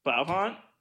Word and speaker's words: Blaupunkt: It is all one word. It's spelled B-L-A-U-P-U-N-K-T. It's like... – Blaupunkt? – It's German. It's Blaupunkt.